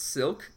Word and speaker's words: Cylk: And there's also a guy, I think his name is pronounced Silk Cozart. C-Y-L-K.